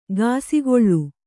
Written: ಕಿಸುಗುಳಿ

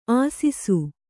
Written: ಆಸಿಸು

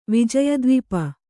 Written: ವಿಜಯದ್ವಿಪ

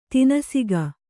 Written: ತನಿಸೊಕ್ಕು

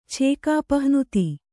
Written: ಛೇಕಾಪಹ್ನುತಿ

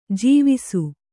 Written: ಜೋವಿಸ

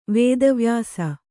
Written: ವೇದವ್ಯಾಸ

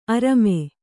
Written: ಅರಮೆ

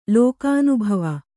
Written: ಲೋಕಾನುಭವ

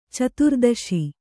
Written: ಚತುರ್ದಶಿ

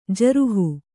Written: ಜರುಹು

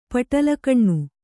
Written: ಪಟಲಗಣ್ಣು

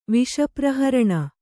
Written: ವಿಷಪ್ರಹರಣ

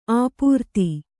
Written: ಅಪ್ರತಿ

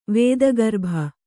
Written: ವೇದಗರ್ಭ

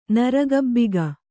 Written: ನರಗಬ್ಬಿಗ

